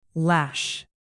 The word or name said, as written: lash